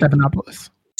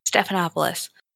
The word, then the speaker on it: Stephanopoulos: Biden, it, Stephanopoulos, I can never pronounce his name. – Stephanopoulos. – Stephanopoulos. Thank you.